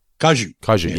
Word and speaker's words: kaiju: Kaiju? – Kaiju, yes.